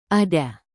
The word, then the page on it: ada: indonesian